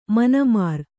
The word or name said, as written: ಮೀನುಮರ